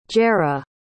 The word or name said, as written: jera